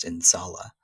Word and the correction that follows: Inshallah: What does that word mean, and why is that word so important to you? – Yeah, it's Inshallah, and you know, it's an Arabic word that's used in so many different ways.